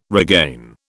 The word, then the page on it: regain: British English